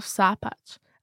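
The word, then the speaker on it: Sàbaid: Now, one thing to point out is the long A of Sapach, and that A has an accent on it, because Sapach, without the accent and with a short A, actually means fight.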